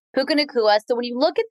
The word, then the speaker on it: Puka Nacua: Puka Nacua. Did I say it right? – You got it. Perfect.